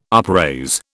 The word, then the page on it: upraise: British English